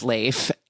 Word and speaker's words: Leif: I was refreshed once again that it's pronounced Leif, and he's reuniting with this guy whom he paralyzed driving drunk and high 20 years before that.